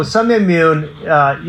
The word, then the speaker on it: Sun Myung Moon: So Reverend Sun, how do you say that? Reverend Moon. – Sun Myung Moon.